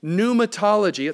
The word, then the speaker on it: pneumatology: pneumatology. It's such a fancy word, it has a silent P in front of it. Pneumatology.